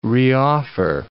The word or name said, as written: reoffer